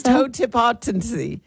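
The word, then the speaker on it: totipotency: It is toe tip appetency. It is spelled T-O-T-I-P-O-T-E-N.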